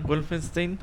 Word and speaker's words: Wolfenstein: Wolfenstein.